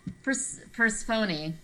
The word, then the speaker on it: Persephone: Because in one, Persephone, because that's what they say in that DLC, that's how you say her name, she goes, you could either have Phoebe or Procetus back.